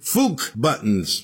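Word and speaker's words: Fuck Buttons: Liars did Hold You Drum, another band called Magic Shop, Interstellar Car Crash, and we heard, Fook Buttons, but it rhymes with luck, and Brain Freeze was the song that started the set.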